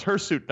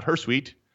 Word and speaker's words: hirsute: It is pronounced hirsute, not hirsute.